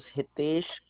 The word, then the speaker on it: Hitesh: First name is Hitesh. Last name is Suri. It's spelled H-I-T like a Thomas, E-S-H.